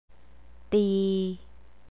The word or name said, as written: tee